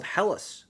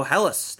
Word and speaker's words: Helles: A German-style lager called Helles, pale in color, offers fresh notes of baked Bavarian pretzels, keeping things on brand.